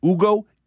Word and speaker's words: Ugo: But if you are interested in learning more about Ugo, it's spelled U-G-O, Ugo. And the designer is Famine, so F-A-M-I-N. But Ugo, U-G-O, is where you can learn more about this tactile stove top.